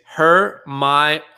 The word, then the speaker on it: Hermione: And the cool thing, I gotta say it, the pronunciation is Hermione.